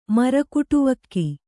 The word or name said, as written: ಮರಕುಟವಕ್ಕಿ